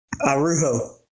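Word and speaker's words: Araujo: Arujo. – The J is pronounced like an H.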